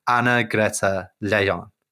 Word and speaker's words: Anna Greta Leijon: And this led to the resignation of the Minister of Justice, Anna Greta Leijon.